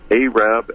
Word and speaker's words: Arab: And that's mostly co-teaching with Phyllis Light, this great herbalist from Arab, Alabama. They make up say that because for various reasons, not a southern accent. It's actually what the U.S. Post Office determined was the right way to pronounce it.